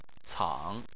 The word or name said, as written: cang3